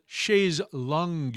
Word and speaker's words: chaise longue: And over the past several years, this dual-level design called chaise longue, it's not spelled like lounge, okay. It's something else. Maybe that's French.